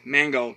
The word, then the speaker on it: Mangold: Uh, I do believe James Magnold. I think it's his pronounced Magnold, uh, Magnold, uh, whatever his name, how you pronounce it. Uh, I'll get to it in a minute. Um, James Mangold.